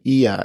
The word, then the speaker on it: Oia: And she said, oh, go to this town, Ia. It's spelled OIA.